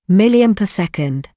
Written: milliampere-second

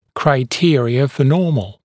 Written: criteria for normal

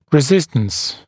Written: resistance

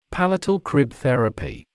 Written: palatal crib therapy

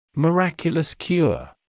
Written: miraculous cure

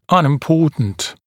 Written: unimportant

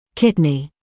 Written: kidney

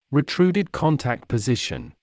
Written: retruded contact position

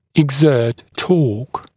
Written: exert torque

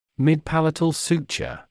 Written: midpalatal suture

